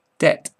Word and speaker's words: debt: B must not be heard in doubt. Debt and dumb both leave it out.